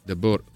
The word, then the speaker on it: De Boer: De Boer, come si pronuncia? – De Boer.